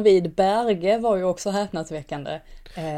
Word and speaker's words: Berge: Jo, det är, han... norskt och det uttalas Berge. Det är bara att, att han av någon anledning försöker få någon slags internationell touch på det.